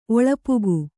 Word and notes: ಒಳಪುಗು: Kannada

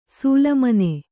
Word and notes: ಸೂಲಮನೆ: sūla mane; Kannada

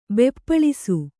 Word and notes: ಬೆಪ್ಪಳಿಸು: beppaḷisu

